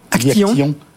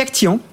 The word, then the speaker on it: Actiyon: Alors vous êtes directeur marketing de Actillon. – Actillon. On dit Actillon. – Oui, ça s'écrit A-C-T-I-Y-O-N.